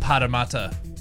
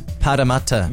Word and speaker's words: Parramatta: It's pronounced Parramatta. – Parramatta, not Parramatta. Yeah. Parramatta.